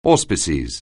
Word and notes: auspices: anglais

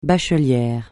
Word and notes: bachelière: francais

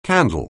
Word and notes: candle: anglais

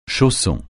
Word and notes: chausson: français

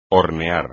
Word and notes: hornear: espagnol

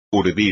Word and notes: urdir: espagnol

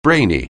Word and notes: brainy: anglais

